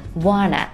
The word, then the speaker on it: wanna: Want to 可以缩读成 wanna, wanna. 这